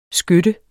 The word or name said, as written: skøtte